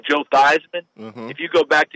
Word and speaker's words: Theismann: everyone will give you a blank look because the real family name is Theismann. Oh. They changed it to Theismann at Notre Dame to rhyme with Heisman, and they were trying to get the Heisman Trophy.